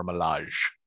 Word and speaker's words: Milaje: D-O-R-A space M-I-L-A-J-E. – Okay, good. That way, I've heard it pronounced it like either the Dora Milaje or the Dora Milaje, depending on. – I've heard both too. Yeah. I always say Milaje.